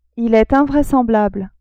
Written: il est invraisemblable

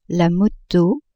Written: moto

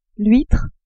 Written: huitre

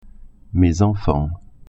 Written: enfants

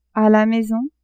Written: a la maison

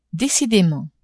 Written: decidement

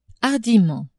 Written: hardiment

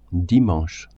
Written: dimanche

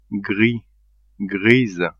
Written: gris-grise

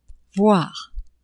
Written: voire

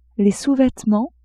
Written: sous-vêtements

